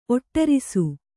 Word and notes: ಒಡರಿಸು: kannada

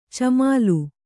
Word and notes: ಚಿಮ್ಮಲು: Kannada

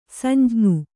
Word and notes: ಸಂಜ್ಞಿ: samjñi

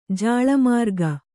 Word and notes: ಜಾಳಮಾರ್ಗ: jāḷa mārga

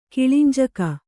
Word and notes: ಕಿಳಿಂಜಕ: kiḷinjaka

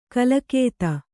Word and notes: ಕಲಕೇತ: kalakēta

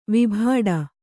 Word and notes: ವಿಭಾಡ: Kannada

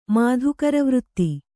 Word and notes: ಮಾಧೂಕರವೃತ್ತಿ: mādhūkara vřtti

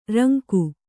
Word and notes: ರಂಗ: Kannada

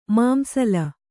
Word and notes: ಮಾಂಸಲ: kannada; māmsala

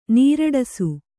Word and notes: ನೀರೂಡಿಸು: nīrūḍisu

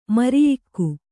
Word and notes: ಮರಿಯಿಕ್ಕು: mariyikku

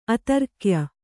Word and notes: ಆಧಾರಗೆಯ್: Kannada

